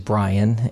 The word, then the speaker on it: Brian: So I tell the guys, and I remind the guys, that my name is Brian, and that rhymes with lion.